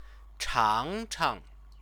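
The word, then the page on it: 尝尝: chángchang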